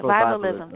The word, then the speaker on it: Afrovivalism: For more than 10 years, their work has metabolized the vernaculars with Black, BL, Diaspora, futurity, and Afro how do you pronounce it? Afrovivalism? Yeah, Afrovivalism.